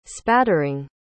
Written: spattering

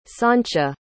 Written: sancia

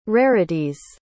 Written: rarity's

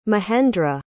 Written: mahendra

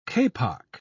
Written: kapok

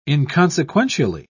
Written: inconsequentially

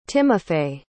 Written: Timofey

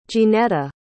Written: jeanetta

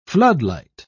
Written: floodlight

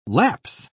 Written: lapse

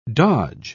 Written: dodge

